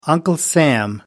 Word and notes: Uncle Sam: American English

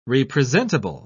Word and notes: representable: British English

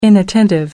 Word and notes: inattentive: American English

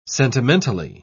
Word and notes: sentimentally: British English

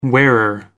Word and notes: wearer: American English

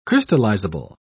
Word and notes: crystallizable: British English

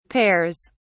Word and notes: pears: British English

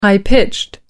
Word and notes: high-pitched: American English

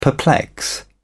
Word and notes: perplex: British English

